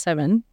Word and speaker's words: Samhain: So, Samhain, it's pronounced Samhain.